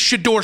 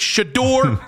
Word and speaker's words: Shedeur: It's not hard. It's Shador Sanders.